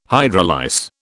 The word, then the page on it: hydrolyse: British English